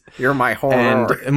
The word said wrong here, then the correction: maror: Sorry, it's not horror, it's maror.